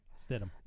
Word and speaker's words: Stidham: It's sad because basically, okay, let's say Stidham or whatever is, I can't pronounce his last name. – Stidham.